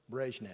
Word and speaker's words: Brezhnev: He attended the funeral of Soviet leader, I think it's Leonid Brezhnev. That's how you say his name.